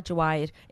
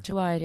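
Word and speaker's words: Jawahier: So if you put the emphasis on the wrong syllable, you're going to change the beautiful meaning. – I'm sorry, Jawahir. – Jawahir, yeah.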